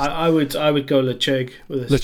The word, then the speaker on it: Ledaig: So it's spelt like it looks like it should be pronounced Ledaig, but I believe it's pronounced Lechug.